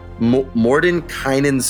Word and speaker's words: Mordenkainen: Mordenkainen's Sword? – Ooh. – Is that how you pronounce it? I don't know. – Mordenkainen, yes.